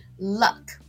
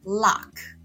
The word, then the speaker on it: luck: ，luck，那大家要注意发音哦，是 luck，不是 lock 哦。因为如果你念 lock 的话，它就变成锁，就是那个门、门锁的那个锁喽，所以要念 luck。